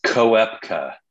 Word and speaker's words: Koepka: And then, I think you foreshadowed, I'm not going to get the pronunciation right here, Kupka? Brooks going over as well. So he's another. – It's Koepka. – Oh, Koepka, sorry.